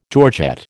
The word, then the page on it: georgette: American English